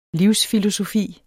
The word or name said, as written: livsfilosofi